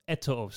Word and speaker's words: Eto'o: Ibra Eto'o style.